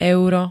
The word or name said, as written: euro